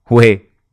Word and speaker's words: uè: So, for example, if you enter your hotel, you can say two formal greetings like salve, buongiorno, but not uè, buongiorno. – If you're greeting someone you're close to, you can say uè, ciao. Oh, did I say it right with the Neapolitan accent? – Uè. Yes.